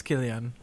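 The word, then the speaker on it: Cillian: Oh, I think it's Killian.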